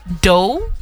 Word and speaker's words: Doue: D-O-U-E. Doe.